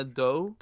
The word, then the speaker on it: Diop: And then later we found out Anna Diop. Is it Diop? I always screw that up. – I've heard some people say without like it's a silent I. So okay. It's like Anna Doe.